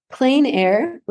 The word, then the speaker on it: plein air: Plein air, which is spelled P L E I N, is a type of painting where artists go out and they paint landscapes live.